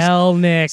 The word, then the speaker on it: Kelenic: I'm probably Kelnick.